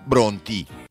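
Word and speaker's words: Brontë: e già lì ho imparato che si pronuncia Bronti.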